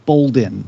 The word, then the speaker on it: Boldin: Is it terrible if I mention that uh, my last name is pronounced Boldin without oh. the R?